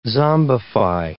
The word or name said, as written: zombify